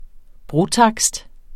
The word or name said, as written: brotakst